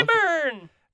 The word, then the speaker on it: Eberron: Because obviously you Eberron.